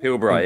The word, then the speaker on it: Pilbara: Pilbara, yeah.